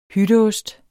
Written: hytteost

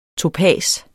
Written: topas

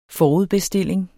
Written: forudbestilling

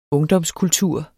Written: ungdomskultur

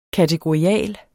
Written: kategorial

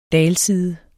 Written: dalside

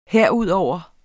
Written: herudover

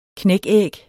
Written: knækæg

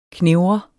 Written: knevre

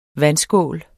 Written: vandskål

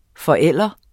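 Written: FORÆLDEREN

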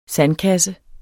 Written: sandkasse

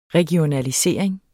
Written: regionalisering

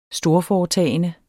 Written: storforetagende

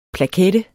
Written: plakette

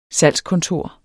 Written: salgskontor

